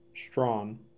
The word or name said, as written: Strawn